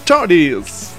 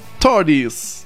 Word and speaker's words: Toadies: occhio alla pronuncia, Vengono da Fort Worth, sì, va bene, in Texas, e sono i Toadies. Non in perugino, non è Toadi in perugino, sono proprio Toadies.